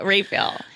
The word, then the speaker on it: Raphael: Yeah, Raphael. – Oh, Raphael. – It's not intuitive because it's, of course, spelled. – It's spelled Raphael. Raphael.